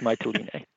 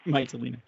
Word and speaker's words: Mytilene: Mytilene, how did you say it? I know you said it properly. – I have to admit, I don't actually speak Greek, so I might not be presenting or pronouncing it properly, but I believe it's Mytilene.